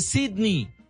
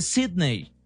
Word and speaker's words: Sydney: Me corrigió, me dijo, no se dice Sydney, se dice Sydney.